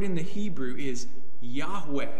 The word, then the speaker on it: Yahweh: But when he's revealing his personal name here, "I am," Yahweh is how it's pronounced, He's showing us how he is a God who is close.